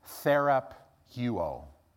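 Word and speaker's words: Therapeuo (θεραπεύω): Therapeuo.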